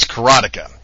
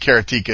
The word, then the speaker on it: Karateka: The game is pronounced universally as Karateka.